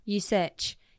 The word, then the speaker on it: Usyk: Usyk.